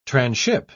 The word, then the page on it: tranship: British English